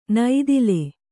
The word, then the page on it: ನೈದಿಲ್: naidil